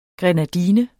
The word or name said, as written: grenadine